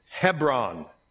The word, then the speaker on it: Hebron: Everybody say Hebron. We grew up in a town, and the next town over was Hebron. We called it Hebron. I didn't know I'd been pronouncing it wrong for a long time, but whatever.